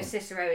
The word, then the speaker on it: Cicero: I listened to a Robert Harris book, a fictionalised version of it, and it was like, Romans probably used the hard C. So although Cicero is how we refer to him as Cicero, and so it's probably less wanky to call him Cicero, it probably was pronounced Cicero at the time.